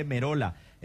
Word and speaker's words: Merolla: Acá me dice Juan Gómez, Wilson, Merolla es, no Merola, no, sí, se escribe Merolla, pero se lee Merola.